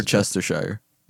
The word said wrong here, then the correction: Cheshire: Yeah. Cheshire, yeah. – That's how you pronounce it.